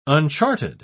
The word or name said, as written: uncharted